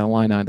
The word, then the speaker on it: Illini: An Illini. – Is that really what it is? – Yeah, Illini. The fighting Illini.